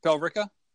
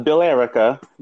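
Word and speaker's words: Billerica: Not Bill Erica. You got it. – Right, exactly. It looks as if it's like America, but with Bill instead of the A.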